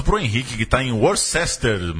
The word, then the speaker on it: Worcester: Pronunciado localmente como Worcester. – Eles falam Worcester. – E a pronúncia se assemelha com Uster.